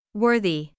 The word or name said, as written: worthy